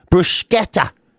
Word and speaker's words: bruschetta: No, apparently you're supposed to put the K sound in. Bruschetta is apparently the proper way to pronounce it.